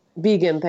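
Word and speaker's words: Biegun: I never know how to pronounce his last name. – Bagan. – Anyway, Bagan, thank you.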